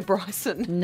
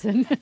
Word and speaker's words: Bryson: Bryson. – Is that Peebo Willison? – Bryson. – Who's Peebo Bryson? – Peebo. – It's like you just added a syllable onto the name Bryce.